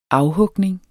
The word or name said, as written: afhugning